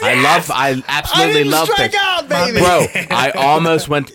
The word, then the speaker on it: PVRIS: Isn't that how it's spelled like that? – P-V-R-I-S. Yes, yeah. Sir.